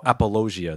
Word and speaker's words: apologia: Apologia? – Yeah. – Yeah. Oh, apologia. That's how it's pronounced.